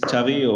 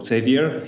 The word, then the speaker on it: Xavi: My name is Xavi, or Xavier, here, how you pronounce it in Sydney.